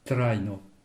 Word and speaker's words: trajno: Traino, ça s'écrit T-R-A-J-N-O. Le J, je le rappelle, c'est un I bref, donc la première syllabe, trai, et la deuxième, no. Traino.